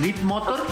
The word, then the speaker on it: Leap Motor: Leap Motor, que se escribe L-E-A-P. – Como brinco en inglés, Leap Motor.